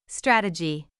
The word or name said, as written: strategy